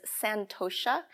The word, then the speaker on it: Santosha: The second one is Santosha, S A N T O S H A, and that's contentment.